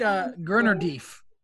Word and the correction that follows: Ganondorf: Gurnardief. Am I saying that right? Gurnardief. – Jackie, you're up. – Ganondorf. – That is correct.